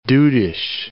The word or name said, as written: dudish